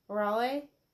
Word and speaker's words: Raleigh: I think I'm saying that first name right. R-A-L-I-E-G-H, Raleigh, like the city.